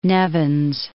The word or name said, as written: Nevins